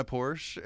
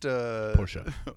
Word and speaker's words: Porsche: Porsche. – Oh. – You don't have a Porsche. – You poser. – That's you're outing yourself as not owning a Porsche by pronouncing it that way.